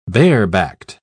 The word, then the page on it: barebacked: British English